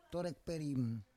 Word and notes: torek perim: Roon

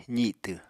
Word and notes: zitw: apatani

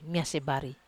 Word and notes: mas bari: Wamesa

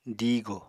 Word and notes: diigò: apatani

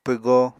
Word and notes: pwgo: Apatani

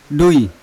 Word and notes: dui: Yawa

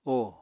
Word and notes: óò: Apatani

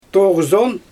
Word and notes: toɣzon: Xyzyl

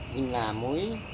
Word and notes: hinamui: Serewen

